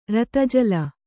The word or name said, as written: ರತಜಲ